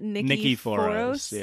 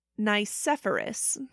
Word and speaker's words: Nicephorus: I wildly mispronounced it. I said it was like, what? Nikiforos? – Yeah. – Okay. So it's actually pronounced Nicephorus. – Mm-hmm. – Which, like, I should know because what I was saying, foros, is kind of like phosphorus.